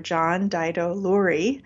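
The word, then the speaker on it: John Daido Loori: And in the foreword to your book, your teacher, John Dido Lurie, sorry if I'm mispronouncing that. – No, you got it just right.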